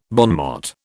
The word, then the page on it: bon mot: British English